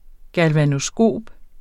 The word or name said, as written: galvanoskop